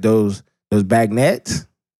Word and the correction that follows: beignets: Beignets. Beignets. Yeah, Cafe Du Monde.